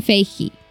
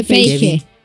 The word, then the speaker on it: Feige: Ah, el, productor, Kevin Feige. – Se escribe Feige. Feige.